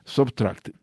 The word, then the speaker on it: SBTRKT: Y un proyecto que me gusta mucho, Subtract, que se escribe sin las vocales, solamente las consonantes S-B-T-R-K-T.